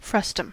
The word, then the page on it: frustum: American English